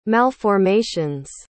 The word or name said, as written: malformations